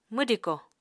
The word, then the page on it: mwdiko: Apatani